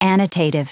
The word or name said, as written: annotative